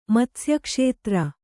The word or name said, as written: ಮತ್ಸ್ಯಕ್ಷೇತ್ರ